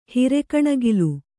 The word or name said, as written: ಹಿರೆಕಣಗಿಲು